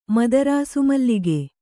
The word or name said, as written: ಮದರಾಸುಮಲ್ಲಿಗೆ